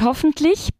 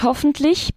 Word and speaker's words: hoffentlich: Der Lärm hört hoffentlich bald auf. – Esperemos que la lluvia cese pronto. – Der Regen hört hoffentlich bald auf. A ver, la pronunciación. Hoffentlich. Dilo tú. – Hoffentlich. – Muy bien.